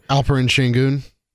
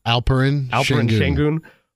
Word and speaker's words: Alperen Şengün: and Eddie, correct me if I'm saying his name wrong, even though I heard it 15 times last night. – Alperen Şengün. – One more time on the first name? – Alperen Şengün.